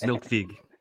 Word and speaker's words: Melkweg: Fish did 3 sets at the Melkweg, you know? I mean, basically, kind of, sort of. And an encore. – I heard it's pronounced Milkweg. – Milkweg.